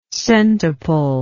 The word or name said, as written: centuple